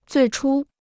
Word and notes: 最初: zuì chū

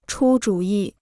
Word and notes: 出主意: chū zhǔ yi

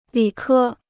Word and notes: 理科: lǐ kē

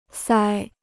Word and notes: 腮: sāi

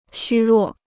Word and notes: 虚弱: xū ruò

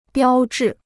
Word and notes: 标致: biāo zhi

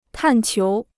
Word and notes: 探求: tàn qiú

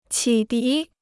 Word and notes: 启迪: qǐ dí

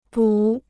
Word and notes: 补: bǔ

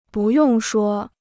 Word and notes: 不用说: bù yòng shuō

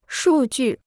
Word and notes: 数据: shù jù

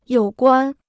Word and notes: 有关: yǒu guān

